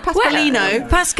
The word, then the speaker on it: Pasqualino: Pasqualino.